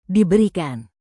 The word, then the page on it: diberikan: indonesian